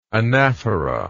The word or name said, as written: anaphoral